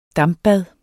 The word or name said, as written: dampbad